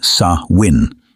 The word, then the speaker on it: Samhain: Before it was called Halloween, before candy and costumes, it was known by another name, Samhain. Pronounced Sa-win, it marked the end of harvest and the beginning of winter, a season of death, dormancy, and decay.